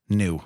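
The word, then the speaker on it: Nu: New.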